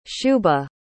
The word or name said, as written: shubha